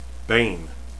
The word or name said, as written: bain